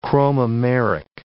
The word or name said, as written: chromomeric